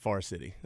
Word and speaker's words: Forest City: That's a better way of saying it. Far City. That's what we Did I say it wrong? – No, we just always shorten it.